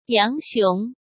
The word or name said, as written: Yang Xiong